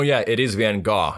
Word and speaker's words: Van Gogh: Well, so the crazy thing about Van Gogh is, first off, his name is actually Van Gogh. It's pronounced differently.